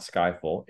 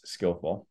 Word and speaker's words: Skyllful: So it's not Skyful, it's Skillful, because we're helping develop the digital skills of the frontline workforce.